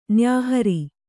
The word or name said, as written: ನ್ಯಾಹರಿ